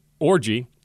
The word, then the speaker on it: Orji: Anthony Orji. linebacker out of Vanderbilt, and I confirm that's how you say his name. It's O-R-J-I.